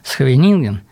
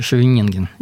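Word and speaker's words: Scheveningen: Вот как раз я вспоминаю, ну, в шахматах известный город Схвенинген, или мы говорим попроще, Шевенинген. Есть вариант шевенингенский. Но мне рассказывали, что когда я был в этом городе, голландцы немецких шпионов ловили именно на произношении города. Даже вот если ты с детства не владеешь голландским языком, то выучить четко произнести Схвенинген, как они гортанные у них, вот это, немцы не могли.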